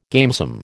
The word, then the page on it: gamesome: American English